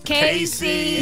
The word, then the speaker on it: Kaci: I don't know how to pronounce this. K-A-C-I. Is this Cassie? Is this Casey? Is it Khaki? – Casey.